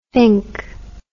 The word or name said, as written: finke